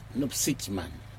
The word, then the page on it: nopsijman: Aneityum